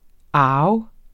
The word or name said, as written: arv